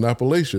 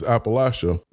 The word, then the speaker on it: Appalachia: Do you find that most people just think the Appalachia, because I've been saying it wrong, I've been saying Appalachia is Appalachia.